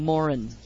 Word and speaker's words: Moran: Well, Dylan Moran apparently pronounces his name Morin. Last name, Morin.